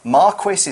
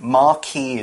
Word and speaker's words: Marquis: Marquis is how we'd say it in the UK. Marquis is more like France or Italy.